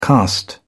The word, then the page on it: caste: British English